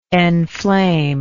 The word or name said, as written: enflame